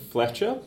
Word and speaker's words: Fletcher: Stefan Fletcher, and that is F for Frank, L-E-T-C-H-E-R.